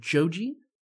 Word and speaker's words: Joji: And it's called Joji, which is the Japanese pronunciation of his name, George, because he's Japanese.